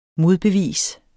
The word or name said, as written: modbevis